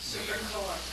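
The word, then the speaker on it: Serpico: but it was something like Serpil. Am I pronouncing it right? – Serpical. – Is it? – Let me see. Serpical? – Serpical.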